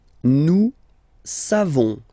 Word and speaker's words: nous savons: Nous savons. So classic ending for nous, O-N-S. You don't pronounce the final S.